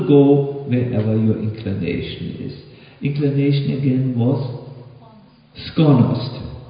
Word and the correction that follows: sklonost: Did I say it correctly? Oh, this is a skonost. Aha, I can say it now. Sklonost. The sklonost, yes.